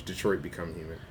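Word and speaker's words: Detroit: Become Human: I'm sorry, I've been saying it wrong for all the Leaguers who are losing their shit right now. It's Detroit Become Human.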